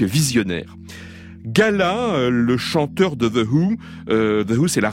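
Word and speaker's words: The Hu: Voici The Who. Hein, ça s'écrit H-U.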